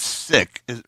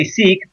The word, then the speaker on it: Sikh: It's Sikh. How do you pronounce it? – Yeah, so anglicized, they say Sikh, but in terms of from the way we pronounce it, it's Sikh. Yeah, S-I-K-H.